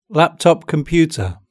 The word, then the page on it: laptop computer: British English